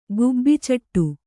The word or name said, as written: ಗುಬ್ಬಿಚಟ್ಟು